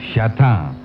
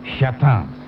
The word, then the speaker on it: Châtain: I want to tell you about Châtain. Châtain is a superb new French wine that is now available in Ireland in red and white. I will spell it for you. C-H-A-T-A-N, Châtain.